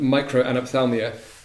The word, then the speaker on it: microanophthalmia: around microanophthalmia and Charles Bonnet syndrome. Really fascinating and I'm sure challenging conditions. But tell us a bit about how that's played out. – Okay, firstly, pronunciation spot on, thank you.